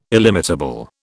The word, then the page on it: illimitable: British English